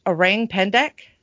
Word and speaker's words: Orang Pendek: This one I actually pronounced right when I said it, and Nick actually told me that. It's Orang Pendek.